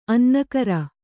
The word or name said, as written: ಅನ್ನಕ್ಕರ